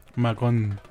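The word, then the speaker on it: Magón: Pero con acento en la O. – Magon.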